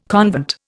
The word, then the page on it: convent: Indian